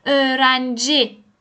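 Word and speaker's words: öğrenci: اتوبوس اتوبوس ارنجی نگاه کن یوموشا که خیلی خفیف تلفظ میشه ا خیلی خفیف رو داره صداشو ارنجی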